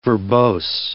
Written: verbose

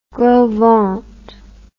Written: Gros Ventre

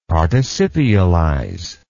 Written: participialize

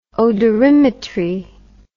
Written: odorimetry

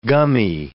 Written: gummy

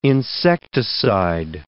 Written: insecticide

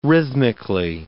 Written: rhythmically

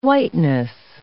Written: whiteness

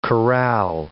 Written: chorale

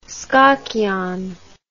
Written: skokiaan

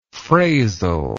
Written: frazil

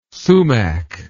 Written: sumac

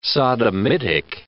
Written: sodomitic

